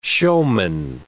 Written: showman

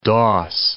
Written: doss

